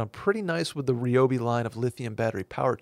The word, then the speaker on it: Ryobi: Ryobi.